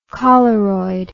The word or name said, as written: choleroid